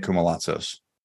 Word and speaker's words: Koumalatsos: If you can find my name, Nick Koumalatsos.